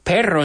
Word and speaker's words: perro: Now, the word perro in English, perro in Spanish is a little bit difficult for English speakers to pronounce, that rolling R.